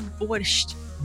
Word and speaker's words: borscht: Borscht. There's no T, though. – You flatlined. – There is a T. It sounds better. I know what's funny. T is funny. – I know it's spelled with a T, but there is no T.